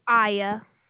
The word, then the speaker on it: Aiya: Aya. – Is it I-I-A or is it, how do you spell it? – A-I-Y-A.